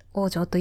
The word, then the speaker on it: 大城: こちら、大きい城って書いて、王城と読みます。